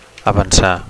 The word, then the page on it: avancar: Catalan